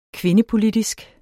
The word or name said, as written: kvindepolitisk